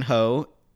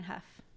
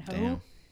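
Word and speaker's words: Hough: Her name's Julianne Huff. – That's how you say it? – Mm-hmm. – That's weird. Why do they spell it like that? – I don't know. Okay. Julianne Huff.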